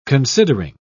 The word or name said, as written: considering